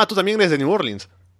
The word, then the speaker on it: New Orleans: Esto se dice New Orleans, New Orleans, ¿no? Pero es New Orleans. Así se pronuncia.